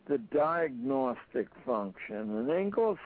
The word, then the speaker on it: Inglis: The second function of schooling, according to this guy's name was Alexander Ingalls, although it's spelled I-N-G-L-I-S,